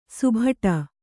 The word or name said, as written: ಸುಭಟ